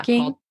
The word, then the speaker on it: Onking: Onking? – Onking. Onking. Yeah, it's spelled O-N-K-I-N-G.